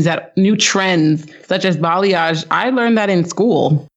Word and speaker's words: balayage: A lot of people say balayage and I'm like, it's balayage.